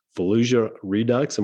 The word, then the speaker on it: Fallujah Redux: You've also co-written a book, Fallujah Redux.